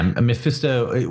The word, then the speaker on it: Mephisto: Doom basically uses himself as bait to capture Doctor Strange on behalf of Mephisto in exchange for releasing his mother. Am I saying that right? – Yeah. Mephisto, it's off panel.